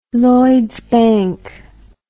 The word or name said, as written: Lloyds Bank